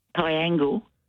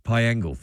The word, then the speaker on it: Piangil: Is it Piangil? How do you pronounce that? P-I-A-G-I-L? – Piangil. – Piangil, thank you.